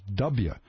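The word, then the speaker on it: Dubya: People on the net refer to George W. Bush as W, D-U-B-Y-A. It's pronounced W.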